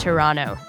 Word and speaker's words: Toronto: Sorry, I meant Toronto. You don't pronounce the T.